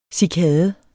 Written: cikade

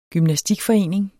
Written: gymnastikforening

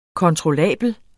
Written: kontrollabel

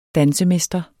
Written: dansemester

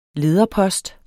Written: lederpost

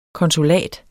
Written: konsulat

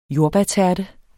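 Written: JORDBÆRTÆRTE

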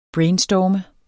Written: brainstorm

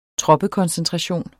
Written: troppekoncentration

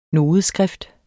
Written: nodeskrift

